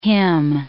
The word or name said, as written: hymn